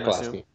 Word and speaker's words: Pulaski: Am I saying that right? Pulaski? – Yeah, Pulaski.